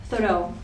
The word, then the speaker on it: Thoreau: Dice lo siguiente: a raíz de estos materiales me he puesto a releer Walden, la obra del escritor norteamericano Henry David Thoreau, que no sé si está bien pronunciado. Thoreau, gracias. Y entonces él, digamos, concluye lo siguiente, ¿no?